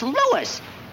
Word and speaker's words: Louis: Huh, sounds like there might be a story at the plant, Lois. – Lois? Me name is Lewis, not Lois. Gee whiz, everybody in Toypolite's me name wrong. It's Lewis. L-O-U-I-S.